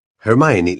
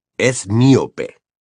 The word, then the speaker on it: Hermione: Hermione le enseñaba a Krum a pronunciar bien su nombre. Él seguía diciendo, «Es miope». – «Hermione», decía ella, despacio y claro.